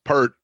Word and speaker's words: Peart: Peart. – Peart.